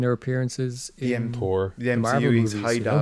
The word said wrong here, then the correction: Heimdall: you know? I can never pronounce his name. Heimdall, or we'll talk to a Norwegian and they'll tell us we're all wrong.